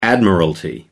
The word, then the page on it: admiralty: American English